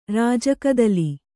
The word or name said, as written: ರಾಜಕದಳ